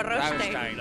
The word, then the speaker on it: Rammstein: Rammstein. Creo que dijo Rammstein. – La pronunció en perfecto alemán.